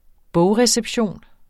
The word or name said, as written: bogreception